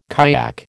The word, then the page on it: kayak: American English